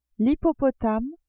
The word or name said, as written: hippopotame